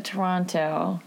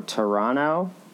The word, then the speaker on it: Toronto: They say, oh, Toronto. And it's like Atlanta, they don't pronounce the last N. – Mm, Toronto?